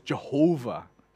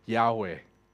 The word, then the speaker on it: Yahweh: Jehovah is a way of people trying to pronounce the word with adding the word of Adonai to the character there. But most likely, the most accurate, more accurate way of calling it is Yahweh.